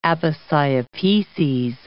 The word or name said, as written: aposiopeses